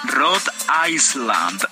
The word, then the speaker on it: Rhode Island: Como dije? Rhode Island. Ok, lo dije bien.